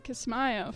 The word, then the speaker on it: Kismayo: Listening for Signs of the Americans in Kismayo, Somalia.